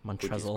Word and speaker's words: Montrezl: There's an L in his name, right? – Correct. It is a silent L. Montrezl.